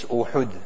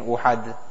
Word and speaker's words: Uhud: The correct pronunciation is Uhud. Generally people say we begin Uhad.